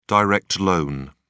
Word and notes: direct loan: British English

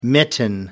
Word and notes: mitten: American English

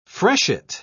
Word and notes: freshet: British English

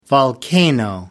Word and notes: volcano: American English

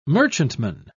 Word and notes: merchantman: British English

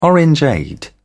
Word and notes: orangeade: British English

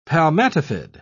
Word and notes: palmatifid: British English

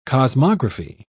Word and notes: cosmography: British English

